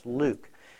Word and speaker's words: LIWC: L-I-W-C, which we pronounce Luke.